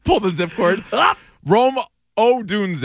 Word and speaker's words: Odunze: Okay, I'm going to go Rome Odunze, who was the House of Fire. – Can you spell that for me? – O-D-U-N-Z-E.